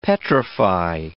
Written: petrify

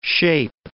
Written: shape

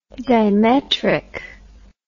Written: dimetric

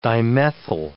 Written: dimethyl

